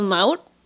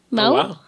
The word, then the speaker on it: Mahou: It's called Maut. It's from Spain, Madrid. Maut. – Oh, wow. – Yeah. – How do you spell it? – M-A-H-O-U.